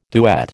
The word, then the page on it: duet: American English